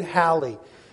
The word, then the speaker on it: Halley: As a matter of fact, it wasn't until 1718 that a fellow by the name of Edward Halley, that's the right pronunciation, you may know it as Halley.